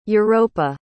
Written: europa

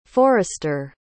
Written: forrester

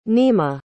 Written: nima

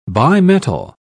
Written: bimetal

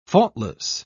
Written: faultless

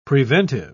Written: preventive

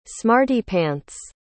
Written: smarty-pants

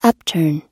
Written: upturn

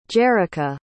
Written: jerica

